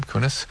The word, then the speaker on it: Kunis: Kunis.